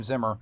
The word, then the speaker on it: Zimmer: You were right the first time, Zimmer.